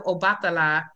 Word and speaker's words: Obatala: She earned a PhD from Harvard University and an MA in history from the City College of New York, an initiate of Obatala and Ianifa. Did I say that right? – Yes.